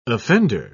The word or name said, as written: offender